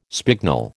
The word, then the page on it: spignel: American English